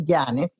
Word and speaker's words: Yanes: Es María Alejandra Celis Yanes. Yanes se escribe con Y.